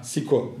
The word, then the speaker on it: Sicko: E, yanlış telaffuz olmasın diye harf harf söyleyeceğim. – S I C K O Siko diye yazılıyor.